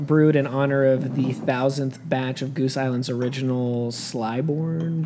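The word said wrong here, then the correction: Clybourn: Brew Pub, I guess. How do you pronounce that? Clybourne. – Yeah.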